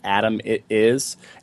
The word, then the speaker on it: Adamitis: Yeah, check me out on LinkedIn, James Adamitis. My last name is spelled like Adam. It is Adamitis.